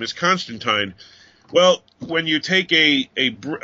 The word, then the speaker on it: Constantine: For those who don't know why we keep stressing teen and time, it goes back to the days of Alan Moore. He created the character, and then at one point he had somebody refer to him as Constantine, and he said Tyne rhymes with wine.